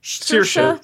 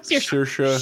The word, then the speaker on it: Saoirse: Saoirse. Saoirse.